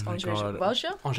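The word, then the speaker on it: Andrzej Wajda: No, I'm not going to pretend that I'm great at Polish names, but I believe it's André Wadja.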